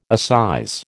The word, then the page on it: assize: American English